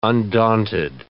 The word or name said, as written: undaunted